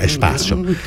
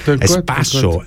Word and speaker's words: Espaço: 19.00 Kompass, Espaço Portugues Brasil, habe ich das richtig ausgesprochen? – Espaço. Espaço.